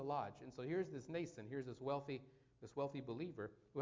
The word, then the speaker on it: Mnason: Also, some of the disciples from Caesarea went," I'm losing my voice, "went with us and brought with them a certain Mnason," the M is silent, "Mnason of Cyprus, an early disciple with whom we were to lodge."